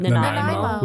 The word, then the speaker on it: Nanaimo: Nanaimo.